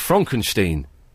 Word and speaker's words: Frankenstein: No, it's pronounced Frankenstein. – So is it Frederick Frankenstein? – No, it's Frederick Frankenstein.